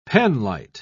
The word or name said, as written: penlight